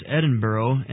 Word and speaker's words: Edinburgh: The capital city of Scotland is pronounced Edinburgh and is the city the Lord has burdened us to reach for Him when we arrive in Scotland.